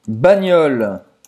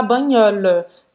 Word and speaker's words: bagnole: Presta atenção aqui nessa pronúncia, tá? Porque você não vai falar nem voiture, nem vai falar caisse nem vai falar banhol, tá? Esse E no final, ele é mudo. – Uhum. E aí, o importante também é esse GN, porque às vezes a gente fica querendo pronunciar bagnola. – Não. Esse GN é como o NH em português, mas um pouco mais forte. Ou seja, bagnol, bagnol.